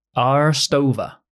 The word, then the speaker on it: Aarstova: Double A. That's a curveball. A-A-R-S-T-O-V-A. Arstova.